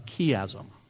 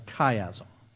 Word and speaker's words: chiasm: Uh, in Greek and in the time of the New Testament, it was common to use a literary device called uh, a chiasm, sometimes pronounced a chiasm. That's C H I A S M.